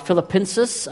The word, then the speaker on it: Filipenses: or Filipenses. I learned that's how you say it in Spanish.